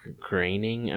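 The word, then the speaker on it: Groening: You know, because I feel like, I mean, Matt, I want to say it's graining. I've heard it reminds me, I've heard it rhymes with complaining. That's how I've remembered it. So it's Matt Graining.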